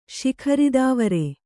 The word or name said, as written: ಶಿಖರಿದಾವರೆ